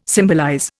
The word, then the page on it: symbolize: Indian